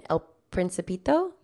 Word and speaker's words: El Principito: El Principito? – Mm-hmm.